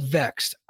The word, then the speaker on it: VXT: I know they're called Vexed. I understand this, but you don't get me to pronounce a vowel unless you give me a vowel.